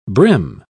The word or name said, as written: brim